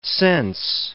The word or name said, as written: sense